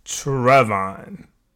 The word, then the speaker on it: Travaughn: Mine is T-R-A-V-A-U-G-H-N. – God damn, that's long. – Calm down. Don't do that. Don't do that, Ramont. So my mother will say Trevon. – For you. – For me. But I say, so it's capital T-R-A, capital V-A.